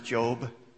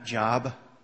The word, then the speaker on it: Job: And it is pronounced Job, not Job, in case you were wondering.